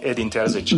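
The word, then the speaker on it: Edin Terzić: So, you can call me Edin Terzic.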